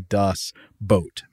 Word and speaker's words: Boot: Carrie informs us that while the German word is spelled B-O-O-T, it is pronounced the same as the English word boat. So it's actually Das Boot, meaning the boat.